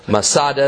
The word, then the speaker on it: Masada: Masada is merely a Roman way to pronounce the Hebrew word Metzada, which means fortress.